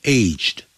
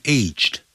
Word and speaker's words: aged: 那 B, aged, 也可以变过去分词 aged。那意思不同，有两种发音，一个念成 aged, 一个念成 aged。